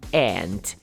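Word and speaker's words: aunt: Tia ant, e é igual a pronúncia de formiga, que é ant também.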